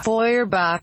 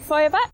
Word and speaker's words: Feuerbach: Das Feuer. Der Bach. Feuerbach. Ludwig Feuerbach.